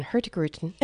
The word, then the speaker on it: Hurtigruten: We're talking with, it's such a fun word to say, Beth, I have to say it again, Hurtigruten.